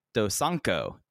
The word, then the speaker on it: Dosanko: and then the Dosako, let me pronounce that again, Dosanko Derby, six-man tag team match, KZ, Big Boss Shimizu, Jackie Funky Kamei versus BB Hulk, Kai, and Hyo.